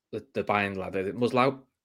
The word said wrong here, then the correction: Musiala: Musli, Musiala.